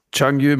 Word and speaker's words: Changyu: Chief Winemaker beim Chateau Changyu, oder wie sagst du? – Changyu Moser, ja, das ist korrekt.